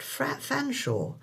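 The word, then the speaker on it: Featherstonehaugh: It would be very interesting to hear how the appointments department computer copes with surnames like Featherstone Hoare, correctly pronounced Fanshawe, and Cholmondeley, correctly pronounced as Chumley, or first names like Sian, correctly pronounced Sean.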